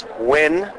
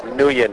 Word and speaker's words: Nguyen: There's two ways to say it. There's Nguyen, which is more of the native Vietnamese pronunciation. And then there's the Americanized pronunciation, which I prefer, which is Nguyen, which is a silent G.